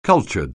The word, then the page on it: cultured: anglais